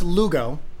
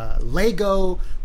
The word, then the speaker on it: Lugo: Well, it's pronounced Lugo, mm-hmm. and it's spelled L-U-G-O. Mm-hmm. And I've gotten all kinds, like Lego, Luego, Lugastis, Lugenstein.